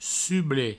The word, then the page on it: sublet: provençal